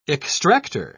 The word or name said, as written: extractor